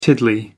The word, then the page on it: tiddly: American English